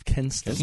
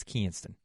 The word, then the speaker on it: Kinston: The booming metropolis of Kinston. – No, you're not saying it right. It's Kinston.